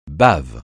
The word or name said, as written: bave